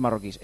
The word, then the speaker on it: marroquíes: Bueno, oye, por cierto, tenemos muchos oyentes marroquíes. ¿Es marroquíes o marroquíes? ¿Cómo se dice? – Marroquíes. – Marroquíes. Como jabalíes. Marroquíes.